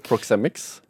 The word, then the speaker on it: proxemics: Ja, altså at Filosofien baserer seg på en studie av noe som på engelsk kalles proxemics. Ja. Er det riktig uttale? Ja da. Proxemics.